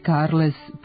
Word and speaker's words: Carles: Katalonski lider zove se Carles Puć demon, što je najpribližnije pravilima katalonskog izgovora, a u jezičko raspetljavanje krećemo od imena. Izvorno piše se Carles i izgovara Carles.